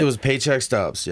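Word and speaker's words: Paycheck Stubbz: It was Paycheck Stubbs. Yeah. – Paycheck Stubbs. – Yeah, okay. – It's spelled S-T-U-B-B-Z. Stubbs.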